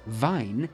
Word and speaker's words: Wein: Conversely, W is pronounced as a V in modern German, which is why the German word for wine is pronounced Wein, although it's spelt W-E-I-N.